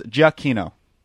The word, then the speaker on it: Giacchino: It's Wikipedia says Giacchino is how it's pronounced.